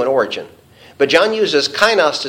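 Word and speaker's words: kainos (καινός): There's neos, and there is the word that's used here, the word that is pronounced kainos, K-A-I-N-O-S.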